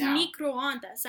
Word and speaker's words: microondas: How do you say it, like, it's microondas. I know how to say it.